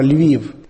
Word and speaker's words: Lviv: Na ukrajinskom to se izgovara Lviv.